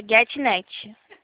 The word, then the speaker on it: Getnet: É Getnet.